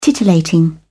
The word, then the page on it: titillating: British English